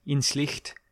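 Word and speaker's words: Inzlicht: And that's to use the correct German pronunciation, Inslicht, a professor of psychology at the University of Toronto and the principal investigator at the Toronto Laboratory for Social Neuroscience.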